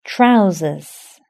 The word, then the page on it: trousers: British English